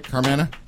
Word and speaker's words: Carmanah: Did I say it right? Carmanah? – Yeah.